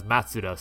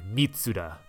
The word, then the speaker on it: Mitsuda: It was pointed out to me that I had accidentally mispronounced Yasunori Mitsuda's name as Matsuda. So there it is, a Secret of the Forest from Chrono Trigger, composed by Yasunori Mitsuda.